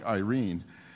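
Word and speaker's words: Irene (εἰρήνη): That peace is the Greek, it actually would be pronounced sort of like Irene. The name Irene is literally Greek for peace.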